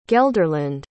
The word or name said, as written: gelderland